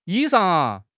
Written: 衣裳

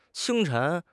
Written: 清晨

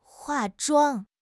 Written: 化妆

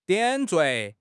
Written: 点缀

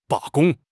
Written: 罢工

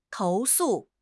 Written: 投诉

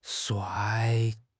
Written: shuai4

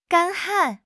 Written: 干旱

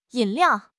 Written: yin3 liao4

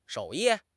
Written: 手艺

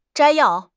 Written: 摘要

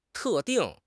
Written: 特定

